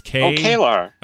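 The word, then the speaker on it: K'Ehleyr: It's Kaelor. – Oh, is it just Kaelor? Yeah. It's Kaelor. That looks like Kaelor.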